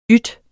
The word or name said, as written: yt